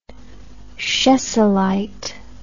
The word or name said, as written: chessylite